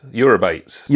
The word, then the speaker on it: Eurybates: So this podcast allows me to like celebrate the smaller discoveries in terms of moons, such as the asteroid moon of Eurybates. – Okay. – How would you pronounce that? – Yes, Eurybates.